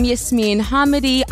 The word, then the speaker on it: Yasmine Hamady: Hi, I'm Yasmeen Hamidi. I actually recently found out that that's how you pronounce my name.